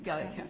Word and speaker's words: Gallia: One other question I just had generally was that, in this case, the EPA, as to Gallia County, am I saying that right? – Gallia County. Yes.